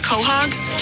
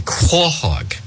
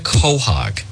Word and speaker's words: quahog: Has she ever had a hot wiener or a quahog? Does she know to stop at the sign of the big lemon? – It's a quahog. It's not a quahog. It's a quahog. They mispronounce it.